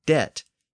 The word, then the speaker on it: debt: So in the 1500s, as English spelling started to become standardized and fixed, it was decided to insert a B in the word debt to indicate that the original Latin word had a B in it. So we ended up with the modern spelling with a silent B.